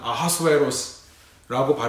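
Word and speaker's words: Ahasuerus: 아람식, 그러니까 히브리식 발음으로는 아하스웨로스라고 발음을 하는데요. 그리스 말로는 Xerxes 혹은 크세르크세스라고 발음을 합니다.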